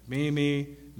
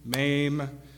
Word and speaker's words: meme: So, first off, most of us spent the whole first part of the decade trying to figure out how to pronounce meme. When it first came out, it was like meme, mame, meh, mom, like 100 different ways. No, it's actually meme.